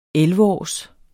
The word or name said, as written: elleveårs